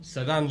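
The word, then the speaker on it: Srđan: Is it Serdan? – Serdan Djokovic, that's the one.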